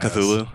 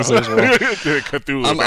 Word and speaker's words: Cthulhu: Cthulhu. – Yeah, there you go. – Cthulhu.